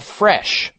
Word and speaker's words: Phresh: It's called Fresh, but it's spelled P-H-R-E-S-H.